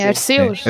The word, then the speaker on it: Arceus: Uh, agora sim então o novo Pokémon Legends uh, Arceus, é assim que se pronuncia? da forma. Sim, uh, sim, sim, sim, é sim. Arceus.